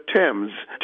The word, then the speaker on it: Thames: We still pronounce the name of the river Thames, even though it is spelled with a TH.